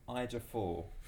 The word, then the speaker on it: Ejiofor: Chiwetel Ifo-fo-fo. The guy that did 12 Years a Slave, the British one. – Never seen it. – The guy that was in... – Chiwetel Ejiofor. That dude.